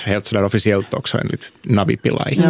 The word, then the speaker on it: Navi Pillay: Var det ungefär rätt? – Och förkortningen var Navi Pillay. – Navi Pillay, ja.